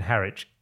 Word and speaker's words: Harwich: Is that how you pronounce it? – Weekend in Harwich. Yeah. I've been to Harwich.